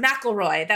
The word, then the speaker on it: McElroy: Oh, actually, I think I pronounced McElroy. That's how they like to pronounce it.